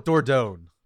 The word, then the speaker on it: Dordogne: I'm going with Dordogne. – I think you're correct. It is spelled D-O-R-D-O-G-N-E.